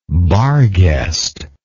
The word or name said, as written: barghest